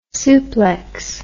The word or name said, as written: suplex